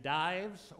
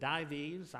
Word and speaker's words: Dives: It is the story of the rich man who was traditionally named Dives, or some pronounce it Dives, I pronounce it Dives, and Lazarus, the rich man and Lazarus.